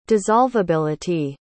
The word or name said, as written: dissolveability